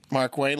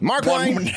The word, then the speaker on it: Markwayne: It's not even, and the W is not capitalized. Nope. It's Mark. – You just say lowercase W-A-Y-N-E. – Say it like this. Mark Wayne. – Mark Wayne, like Mark Twain.